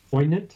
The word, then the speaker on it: poignant: You've twice said poignant, and I'm pretty sure the G is not pronounced in that word. – Poignant? – I think it's poignant.